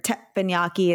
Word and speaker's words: teppanyaki: You, I mean, you can get teppanyaki, or teppanyaki is actually how you're supposed to pronounce it, anywhere you literally can go to Benihana and get mm-hmm.